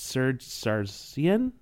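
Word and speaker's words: Serzh Sargsyan: it's Serge Sargsyan? Is that how you say it? – Yep. Mm-hmm.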